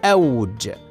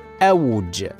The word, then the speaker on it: أوج: من كلمات اللغة العربية كلمة أوج هكذا تنطق بفتح الهمزة وسكون الواو فنقول أوج ولكن البعض ينطقها بفتح الهمزة وضم الواو وهذا خطأ شائع والصواب نطقها هكذا أوج